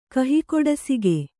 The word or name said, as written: ಕಹಿಕೊಡಸಿಗೆ